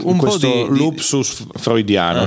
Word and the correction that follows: lapsus: Non è un lupsus? Non si dice lupsus? Si dice lapsus. – Sì. – Sì. Questo era un lapsus.